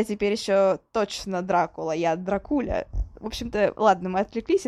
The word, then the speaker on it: Дракула: И недавно я узнала, что правильно говорить не Дракула, а Дракуля.